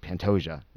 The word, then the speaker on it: Pantoja: And then we have the two championship fights, which is Brandon Moreno, who's minus 200, who's taking on Alejandro Pantoja. Is that, I'm saying that right? – Alexandre Pantoja. – Pantoja.